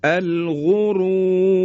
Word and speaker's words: الغرور: مثال بعدی الغرور. در اینجا هم باز دو بار ما را رو تفخیم میکنیم، یکی رای اولی که ضمه هست، رو، رای دوم هم که رای ساکنی که قبل از اون صدای کشیده است، به این شکل، الغرور.